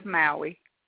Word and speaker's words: Maui: It is Maui.